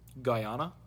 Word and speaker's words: Guyana: Guyana.